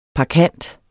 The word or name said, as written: parkant